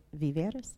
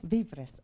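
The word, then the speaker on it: Víveres: So one of the things that our church did in Guatemala was Viveres. I always mispronounce. – Viveres. – Okay.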